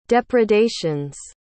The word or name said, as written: depredations